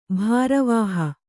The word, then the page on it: ಭಾರವಾಹ: bhāravāha